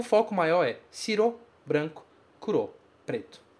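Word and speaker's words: kuro (黒): A pronúncia desse kanji é kuro.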